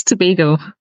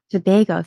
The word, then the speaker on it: Tobago: Tobago. Am I saying that right? – It's Tobago. – Tobago. Thank you.